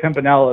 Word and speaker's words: Pimpinella: It's Pimp in Ella.